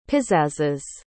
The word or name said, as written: pizzazzes